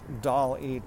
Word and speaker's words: DALL-E: So it's DALL-E 2, D-A-L-L-E 2. DALL, like rhymes with wall.